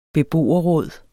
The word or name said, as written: snarest